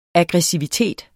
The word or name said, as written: AGGRESSIVITET